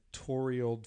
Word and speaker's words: toroidal: It is a coherent resonance in a toroidal field. God damn it. – Spell it. – T-O-R-O-I-D-A-L.